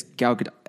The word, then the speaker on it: Gal Gadot: It's Gal Gadot.